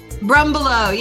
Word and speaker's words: Brumbelow: Brumbelow, yes.